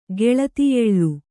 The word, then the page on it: ಗೆಳತಿಯೆಳ್ಳು: Kannada